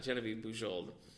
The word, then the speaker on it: Genevieve Bujold: So it's Michael Crichton and Genevieve Bujold, and it's... – That was a great pronunciation of a French name.